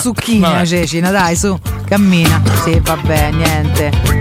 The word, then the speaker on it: Cecina: È Cecina l'accento, non è Cecina ma No, no, è Cecina, Cecina, Cecina.